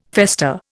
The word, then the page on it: fester: Indian